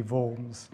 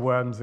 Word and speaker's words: Worms: My German student son has confirmed to me that it should be Worms. So I'll try and stick with that. But if I slip into Worms occasionally, you'll kind of understand where we're going.